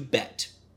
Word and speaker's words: Bette: So it's B-E-T-T-E. They change it from Betty to Bette. And her full name is Mary Elizabeth Kane. She goes by Bette. – But there are some women who spell it like that and it's pronounced Betty, like Betty Davis.